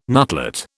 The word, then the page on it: nutlet: British English